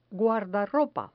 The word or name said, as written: guardarropa